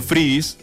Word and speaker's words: freebies: Es como frisbee, pero sin la S, para la pronunciación. Oh. Freebies. Porque quiere decir free, de gratis, y bees, pues es como una conjugación de BTS.